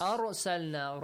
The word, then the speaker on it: أرسلنا: أرسلنا. Ra must be pronounced heavily.